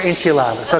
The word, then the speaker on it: enchiladas: Tacos, tamales, enchifladas, ¿no? Ah, siempre lo digo mal, ok. Pero son chiflados por enchiladas, ok.